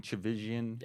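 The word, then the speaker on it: Tchividjian: I can never pronounce it. – Chavisian. – Chavisian. – There we go.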